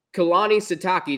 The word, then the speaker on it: Kalani Sitake: So, Jake, my first question to you is, unlike Scott Satterfield, your head coach, Kalani Sitake, did I pronounce that right? – You got it.